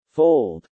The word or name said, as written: fold